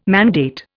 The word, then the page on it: mandate: Indian